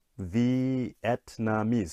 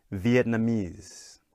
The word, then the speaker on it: Vietnamese: Okay, so now let's look at the nationality, Vietnamese. So again, four syllables. Please listen carefully. Vietnamese. One more time. Vietnamese. Okay. So again, in this case, the "mies" gets the stress.